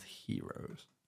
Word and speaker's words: gyros: It's not spelled, but I think it's pronounced heroes. – I've never heard it pronounced like that.